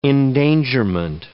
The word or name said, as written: endangerment